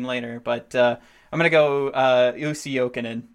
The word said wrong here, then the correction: Jussi: It's actually Jussi.